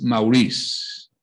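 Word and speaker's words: Maurois: Andrés Maurice, o como, di, como dirían los franceses, que la S no se pronuncia, pero bueno, la vida es un juego en que nadie puede retirarse llevándose las ganancias.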